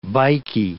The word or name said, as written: bikie